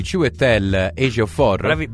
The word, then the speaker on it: Chiwetel Ejiofor: Questo è il nome del personaggio interpretato di Chiwetel Ejiofor.